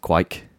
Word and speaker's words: kveik: So it's the word is spelled kveik. – Yeah. – Apparently pronounced quike.